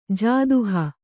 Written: ಜಾದುಹ

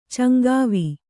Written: ಚಂಗಾವಿ

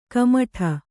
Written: ಕುಮುಟು

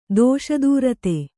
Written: ದೋಷದೂರತೆ

